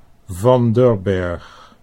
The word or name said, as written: Van der Berg